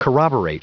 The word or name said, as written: corroborate